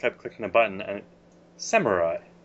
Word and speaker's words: samurai: She insisted that it was samurai, and I kept telling her, like, Mom, it's a Japanese word, and that syllable does not exist in Japanese.